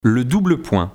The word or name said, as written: le double point